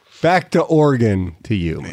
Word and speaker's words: Oregon: And Oregon's like, nobody cares. You didn't even say no one cares. – It's pronounced Oregon. So Oregon. That's shame on you.